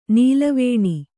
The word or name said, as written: ನೆಲವೆಣ್ಣು